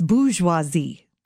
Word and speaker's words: bourgeoisie: It's not burr. It's bourgeoisie.